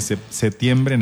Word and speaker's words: septiembre: Yo más bien lo escribo con P, pero lo pronuncio sin P. – Lourdes Matarrita dice que septiembre nada más.